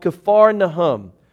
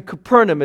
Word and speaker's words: Capernaum: So he heads along the Sea of Galilee to the town in the northwest called Kfar Nahum. And that's actually Capernaum is how we would pronounce it today.